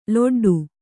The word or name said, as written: ಲೊಡ್ಡು